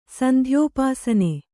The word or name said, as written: ಸಂಧ್ಯೋಪಾಸನೆ